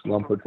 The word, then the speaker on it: Lompoc: You grew up in Lompoc. Is that the right way to say it? Yep. Lompoc? – Yes, Lompoc, California.